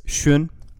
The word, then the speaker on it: Xun: It's Shun. – Shun. – It's spelled X-U-N.